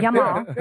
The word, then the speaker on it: Yamal: I mean, Yamal. – Jamal with a Y. – Okay, right. Yamal.